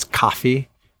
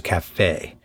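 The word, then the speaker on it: caffè: It's coffee. Coffee is how it's supposed to be pronounced. But nobody ever. – Like the really, hard K. – Hard K and E as opposed to cafe. – Coffee. – As opposed to a soft E.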